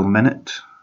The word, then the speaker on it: Minit: Oh, there was another small game that I played on the Game Pass as well, Minute. It's spelled like M-I-N-I-T, but also it means like minute as in 60 seconds, because basically you play 60 seconds at a time.